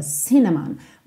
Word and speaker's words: cinnamon: Essa palavra canela em inglês, cinnamon, tem a sílaba tônica na primeira sílaba, cinnamon.